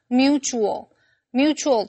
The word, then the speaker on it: mutual: 这篇文章我们第一个来看的是 mutual 这个形容词。注意 T 在这里是发 ch 的音，所以念成 mutual。Mutual